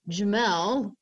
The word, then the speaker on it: Jamelle: After 10 days, a couple from LaGrange, Indiana picked me up, adopted me, and gave me a name, Jamel Rose Olds. Jamel is spelled J-A-M-E-L-L-E.